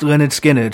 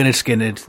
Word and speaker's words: Lynyrd Skynyrd: On this day in 1973, their debut album called Pronounced Lynyrd Skynyrd, so people could know what they were pronounced as, because it got confusing. People were saying Lynyrd Skynyrd or other things, but it's definitely Lynyrd Skynyrd.